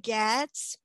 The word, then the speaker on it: Goetz: So my name is Bracha Goetz.